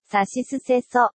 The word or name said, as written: さしすせそ